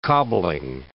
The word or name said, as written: cobbling